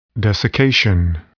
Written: desiccation

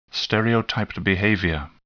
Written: stereotyped behavior

